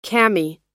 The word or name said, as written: cami